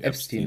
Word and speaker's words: Epstein: Ähm, also im Deutschen sagt man halt Epstein, aber eigentlich ist das Jeffrey Epstein.